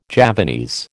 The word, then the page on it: Javanese: American English